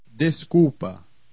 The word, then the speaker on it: Desculpa: In the combination UL plus consonant, the L is eaten by the preceding U. Pronounce these examples. – Desculpa.